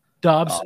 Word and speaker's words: Dobbs: How do you say his last name? Dobbs.